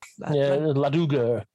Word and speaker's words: Laduguer: To the point where it gets that thing with the hero, and I know I'm going to say their name wrong, the Ladugar. – Ladugar.